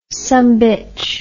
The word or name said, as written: sumbitch